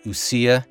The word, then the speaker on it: Ousia: It's spelled O-U-S-I-A, Usia.